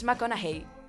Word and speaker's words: McConaughey: Pero, ¿cómo pronunciáis? Bueno, es que si lo voy a decir yo, no va a tener sentido. Matthew McConaughey. – McConaughey. – Es McConaughey.